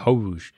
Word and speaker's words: Hoge: Like hose, but with a hoge. – Which I think the lineman out of BYU this year that got drafted, he spells it H-O-G-E, and I'm pretty sure that's how he pronounces hoge.